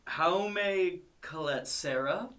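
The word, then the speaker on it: Jaume Collet-Serra: Jaume... Colette Serra.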